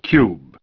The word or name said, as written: Cubed